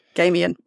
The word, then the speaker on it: Gaiman: Gaiman, yep. – Sorry. – It rhymes with Damien, it works.